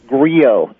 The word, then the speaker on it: Griot: Can you tell us how we pronounce that? – It's Griot, actually.